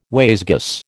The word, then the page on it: wayzgoose: American English